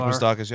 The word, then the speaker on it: Moustakas: You got to worry about Moustakas. Am I saying that correctly? – Yes, Mike Moustakas. Yep.